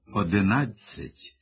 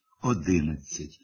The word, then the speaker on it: одинадцять: Замість правильного «одинадцять» з наголосом на третьому складі він мовить «одинадцять», наголошуючи другий склад.